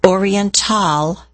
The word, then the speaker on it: Orientale: Topping their science target list, Oriental Basin, and that's spelled O-R-I-E-N-T-A-L-E, Oriental Basement, Basin, pardon me, Oriental Basin.